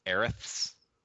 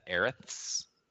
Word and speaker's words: Aerith: Aerith's, how do you say that? Aerith's It's Aerith, but we grew up with Aerith.